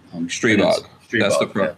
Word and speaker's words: Streebog: Streebog. That's the proper pronunciation of it.